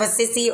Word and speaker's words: Assisi: It's called the Peace Prayer by St. Francis of Assisi. Or as Father Haggerty would say, St. Francis of Assisi. Lord, make me an instrument. St. Francis of Assisi or Assisi. It's pronounced both ways.